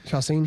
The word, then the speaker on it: Chacin: 2005, Josh finished the season with 13 wins, tied for the club lead, along with rookie Gustavo Chacin. Did I say it right? – Yeah. – Chacin.